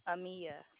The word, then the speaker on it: Amia: My name is Amia, the supervisor you've requested. How may I assist you? – What is your name? – Amia. – How do you spell that? – A-M-I-A. – A-M-I-A.